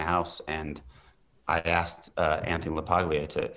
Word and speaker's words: LaPaglia: I went up to Anthony LaPaglia, I think that's how you pronounce his name. – Oh, yes.